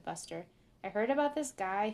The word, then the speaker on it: Buster: Well, when I say Busker, I was saying it wrong, because his name is Buster.